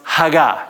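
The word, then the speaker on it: haga (הגה): What it says is actually this word meditating in the Hebrew, the original language, is a word pronounced haga. Everybody say haga. And if you're a good Jewish scholar, you would have that kind of raspy sound in the back of your throat.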